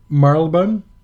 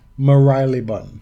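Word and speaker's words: Marylebone: And what Vern Jones is referencing specifically, I think, is the overall experience of Marlebon. – No. No, but how enthusiastic Rafa was to say it incorrectly. – Yes, that was my favorite one, where Rafa says Maraliban, which, pronounce that shit phonetically.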